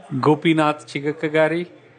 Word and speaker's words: Gopinath Chigakkagari: Gopinath Chigakkagari.